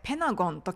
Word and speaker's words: Pentagon: アメリカ英語では、ペンタのんたのところが、えー、 t が発音されなくなって、ペナゴンと聞こえることがあります。